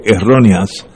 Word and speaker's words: erróneas: Todavía las enseñanzas erróneas, acento en la E de erróneas, de Trump todavía han terminado esa nación.